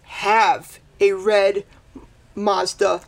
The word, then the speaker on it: Mazda: And so in the first interview, Twitchell was like, never met John, and also I'd never seen a red Mazda hatchback. – Mazda. You said it wrong.